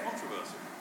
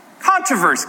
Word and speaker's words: controversy: It's not controversies? Not controversies. How do you say it? Controversy. Controversy.